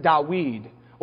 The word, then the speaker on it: Dawid: David's name in Hebrew is three letters. It's a D, a W, and a D. You pronounce it Dawid.